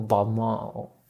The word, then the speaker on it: Balmain: Sí se llama Balmain, para que no sepa, Balmain. ¿Cómo se pronuncia en francés? Balmain. Y yo estudiaba francés, imagínense. bueno, Balmain, Balmón. Balmón. ¿no? algo así, es como Balmón.